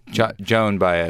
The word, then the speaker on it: Joan Baez: Did you by accident, or did I just mishear? Did you say John Baez? – Joan Baez. Okay, Just a pronunciation issue of the whole interview.